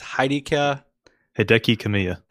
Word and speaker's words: Hideki Kamiya: Is Hideki Kamiya.